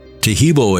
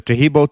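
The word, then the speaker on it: Taheebo: Taheb-O is spelled T-A-H-E-E-B, like boy, O, then the word T, and then the word club.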